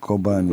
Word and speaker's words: Kobani: Kobani nasıl okunuyor, doğru mu telaffuz ediyorum? – Evet, Kobani. Böyle Kobani. Doğru. Hani kapalı E ile I arası bir ses.